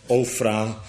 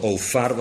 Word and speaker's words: Ofra: Ofra, ook wel ofar, want zo kun je het ook uitspreken, betekent dus gewoon vruchtbaar.